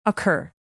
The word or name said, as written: occur